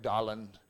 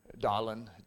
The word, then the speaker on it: Darlan: The way that Darlan is pronounced in French, because this is a French name, sounds, I remember, all of you that do know French know that I'm not very good at it, but it's like Darlan, Darlan. It's sort of like the R gets lost somehow in there,